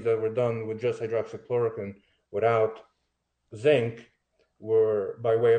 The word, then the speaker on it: hydroxychloroquine: So hydrochloroquine, does it work? – You're saying that wrong. It's hydroxychloroquine.